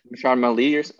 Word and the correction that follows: Mahershala Ali: I can't say it correctly. – Mahershala Ali, yeah.